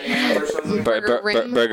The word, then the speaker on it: Burgerim: Burgerim. – Burgerim? Oh, that's how you pronounce it? – Yeah, sorry. It's Hebrew, and the im is like a plural sound. So Burgerim is burgers in Hebrew.